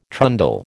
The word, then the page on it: trundle: American English